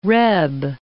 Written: reb

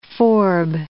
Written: forb

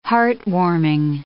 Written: heartwarming

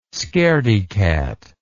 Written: scaredycat

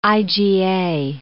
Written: IgA

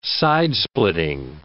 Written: sidesplitting

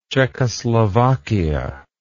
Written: czechoslovakian

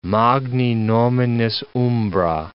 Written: magni nominis umbra

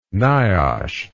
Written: niosh

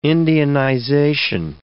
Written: Indianization

